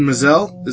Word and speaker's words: Maazel: But uh, Lincoln Maazel, is that how you pronounce his name? Yeah. Uh, uh-huh. his correct.